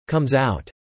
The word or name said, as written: comes out